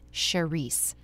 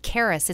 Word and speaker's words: Charis: Top mispronounced shot at my name, Charis, and most people pronounce it Charis. It is not. It is Charis. It's a hard CH like character or charisma. It's the same root. It's like a Greek CH.